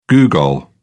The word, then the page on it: googol: British English